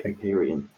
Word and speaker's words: Empyrean: So number two on our list is the Empyrean gameplay. Am I saying that right? – Empyrean.